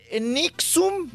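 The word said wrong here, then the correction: NXIVM: Nexium. ¿O ¿Cómo se llama? – Nexium. Nexium.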